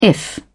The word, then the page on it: if: British English